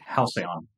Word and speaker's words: halcyon: Is that how you say it? I only read that word. – Halcyon. – Oh, see? Thank you.